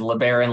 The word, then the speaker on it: LeBaron: A lot of people hit me with LeBron. It's like, unfortunately, no. It is LeBaron like the Chrysler, not LeBron the superstar basketball player.